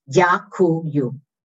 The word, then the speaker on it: Дякую: So thank you is three syllables. Дякую. D-Y-A, дякую, Y-U. Дякую. – Дякую.